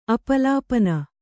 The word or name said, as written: ಅಪಲಪನ